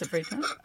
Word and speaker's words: sofrito: How do you say the sofrito? – Yes, sofrito.